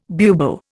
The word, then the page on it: bubo: Indian